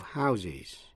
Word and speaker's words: house: A row of houses. Quý vị nghe lại. A house. A row of houses. Một dãy nhà. Với số nhiều, houses, phát âm với âm ears. To house, phát âm với âm z, là cho tạm trú hay là cấp nhà cửa.